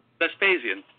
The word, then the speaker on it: Vespasian: And one of the things is this Emperor Vespasian, is that how you pronounce it? – Vespasian.